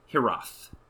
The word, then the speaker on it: Hiraeth: But the next one is, man, this is a really hard word to pronounce, but Hiraeth, H-I-R-A-E-T-H, Hiraeth, something like that.